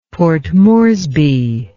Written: Port Moresby

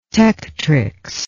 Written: tectrix

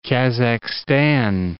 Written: Kazakhstan

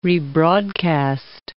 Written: rebroadcast

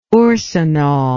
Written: orcinol

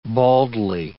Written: baldly

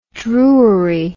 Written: Drury

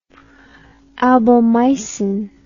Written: albomycin

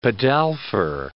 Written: pedalfer

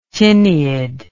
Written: tineid

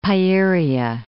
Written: Seleucia Pieria

